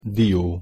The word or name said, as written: dio